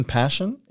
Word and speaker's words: Pashen: Hold on, Ian Passion? – Yeah. – Is that a bloke? – It's spelled P-A-S-H-E-N.